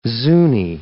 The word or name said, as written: Zuni